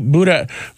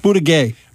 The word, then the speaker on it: Buttigieg: There were a lot of people who I was talking to who were like, ¿Quién es el Pete Buttigieg? ¿Cómo se dice? – Buttigieg.